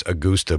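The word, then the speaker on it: Agusta: I always get that wrong. I actually do know that it's Augusta, but I tend to say it wrong.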